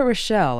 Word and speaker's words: Rochelle: Rita Rochelle is spelled R-I-T-A. Rochelle is R-O-C-H-E-L-L-E.